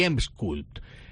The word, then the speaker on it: Emsculpt: Y para eso tenéis dos máquinas en Pelvicali que son Exilis y Emsculpt. Explícanos, ¿qué máquinas son estas? Y sobre todo, ¿lo he pronunciado bien? – Perfecto.